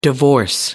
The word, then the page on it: divorce: American English